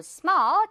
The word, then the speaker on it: small: Small 아니고 small.